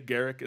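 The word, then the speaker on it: Garruk: While all of this is going on, Liliana is being hunted by Garrick Wildspeaker, I should say. Garrick is how you pronounce it.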